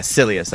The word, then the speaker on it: Silius: Yes, yeah, Silius, that's how you say it.